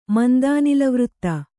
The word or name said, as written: ಮಂದಾನಿಲವೃತ್ತ